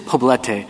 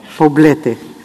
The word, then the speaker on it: Poblete: Well, how do you say it? – Poblete. – Poblete. Okay. I should have said it in Spanish to start with. It is Poblete. That's what happens when I try to Americanize phrases.